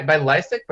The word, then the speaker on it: Lisec: I go by Lisec, but I don't know what the original pronunciation of the name is. It could be Lisec, it could be Lisec, it could be something else entirely.